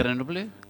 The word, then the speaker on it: Grenoble: tengo que saber cómo se pronuncia Grenoble en francés. Grenoble, ¿puede ser? No sé. – Grenoble. – Grenoble.